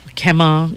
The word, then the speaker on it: Kema: And and you know, Mayor Kawakami and his Kema team, Kema, if you want to pronounce it in a Hawaiian Hawaiian dialect. K-E-M-A.